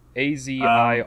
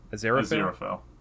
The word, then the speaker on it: Aziraphale: His name was Asriophil. – Sorry, say again? – Asriophil? I don't know if I'm pronouncing that right. – Asriophil. – Yeah. – Maybe. – Azirapale. So Asriophil. – Azirophil. Yeah, that's okay.